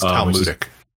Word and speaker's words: Talmudic: I believe it's Talmudic. – Talmudic? – The Talmud.